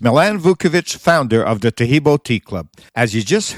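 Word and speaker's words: Taheebo: Taheb-O is spelled T-A-H-E-E-B, like boy, O, then the word T, and then the word club.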